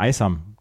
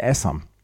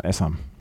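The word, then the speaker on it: Aisam: Ähm, der pakistanische Doppelspieler, weil er mich fragte nach Kuleshi und dann habe ich gesagt, wie wird denn der Vorname ausgesprochen, weil ich habe mir immer Aysam gesagt und er sagte Essam. Und äh, seitdem sage ich dann auch Essam.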